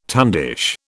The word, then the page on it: tundish: British English